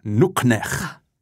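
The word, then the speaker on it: nuqneH: Nuknech. Habe ich das richtig ausgesprochen? – Ja, genau. Nuknech.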